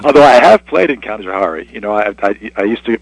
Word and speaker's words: Canajoharie: I mean, that's what we say up here is Canajoharie. You had it a little... – I had to rhyme it with safari, so I didn't know how to do it exactly. – Yeah, it was Canajoharie. But in all honesty, you're using what we pretend to be, or we think might be, the Mohawk pronunciation,